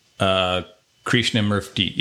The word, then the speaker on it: Krishnamurti: So it's originated with this gentleman named, I believe this is how you pronounce it, Krishnamurti. Does that sound right? – Krishnamurti, Jadu Krishnamurti.